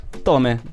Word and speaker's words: Tomae: Voglio chiamare alla latino con l'ittongo. – Ok. Perché si scrive Tomae, sì, però effettivamente Tome.